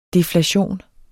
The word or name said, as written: deflation